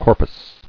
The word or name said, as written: Corpus